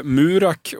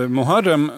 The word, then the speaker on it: Muharrem: gick upp och uttalade sitt eh, missnöje med både Murak och Murahem. Eh, och att kandidaten i fråga hette Muharrem, det hade de lite demonstrativt valt att inte lära sig.